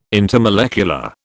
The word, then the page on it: intermolecular: British English